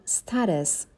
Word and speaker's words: status: یا status که تلفظ امریکنش هست.